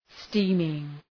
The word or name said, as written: steaming